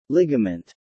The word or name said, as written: ligament